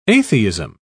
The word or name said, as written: atheism